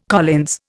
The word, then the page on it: Collins: Indian